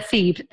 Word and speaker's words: Thebe: Hello, welcome back to this week's episode of Fit and Chips Chats with me, your host, Amanda Thebe, and not Amanda the B, like my guest today, Jane Lewis, thinks my name is pronounced.